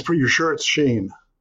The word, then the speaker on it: Shein: They are, and it's pronounced Shein, Bill.